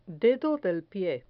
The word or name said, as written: dedo del pie